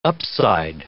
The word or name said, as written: upside